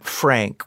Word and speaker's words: Phranc: Phranc, with a PH and a hard C, is how she would always describe herself.